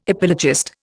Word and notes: epilogist: Indian

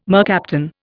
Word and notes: mercaptan: Indian

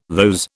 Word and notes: those: British English

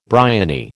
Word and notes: bryony: American English

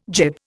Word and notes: gib: Indian

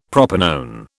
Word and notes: propanone: British English

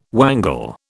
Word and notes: wangle: British English

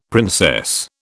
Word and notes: princess: British English

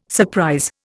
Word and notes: surprise: Indian

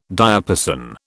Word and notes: diapason: British English

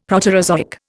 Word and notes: Proterozoic: Indian

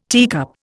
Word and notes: teacup: Indian

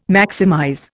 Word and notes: maximize: Indian

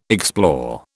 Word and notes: explore: British English

